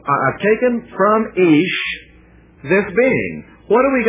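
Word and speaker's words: Ish (איש): And let me pronounce the Hebrew terms so that we'll get what is being said. She shall be called Isha because, verse 23, she was taken out of Ish.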